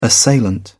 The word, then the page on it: assailant: British English